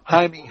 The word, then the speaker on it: Jaime: And then Jamie Jacquez Jr., 6'6". – Jaime Jacquez.